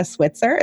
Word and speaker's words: Switzer: I am here with the lovely Becca Switzer.